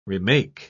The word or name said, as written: remake